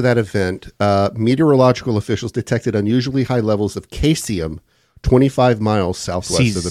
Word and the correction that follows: caesium: Cesium. – Cesium. It's C-A-S-E-I-U-M. Mm-hmm. It's pronounced cesium? Mm-hmm.